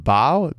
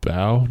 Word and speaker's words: Baugh: However, it came to the attention of the young earth creationist Carl, I think it's pronounced Bau, B-A-U-G-H. You're familiar with him? – Bau?